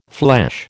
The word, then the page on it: flesh: American English